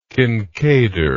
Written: kinkaider

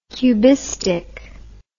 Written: cubistic

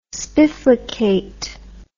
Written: spiflicate

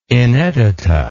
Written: inedita

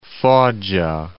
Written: Foggia

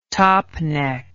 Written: topneck

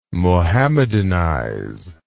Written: Mohammedanize

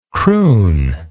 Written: croon

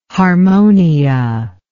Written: harmonia

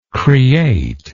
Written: createdness

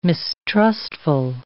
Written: mistrustful

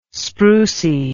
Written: sprucy

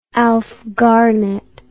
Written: alf garnett

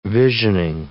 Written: visioning

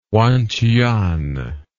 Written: Wanchuan

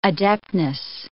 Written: adeptness

